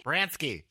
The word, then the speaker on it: Baranski: Did I even say that right? Baranski? – Baranski? – Baranski, yes. – Put the Polska in it.